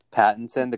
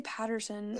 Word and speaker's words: Pattinson: Pattinson, the guy from Twilight? – Yeah, I say Patterson.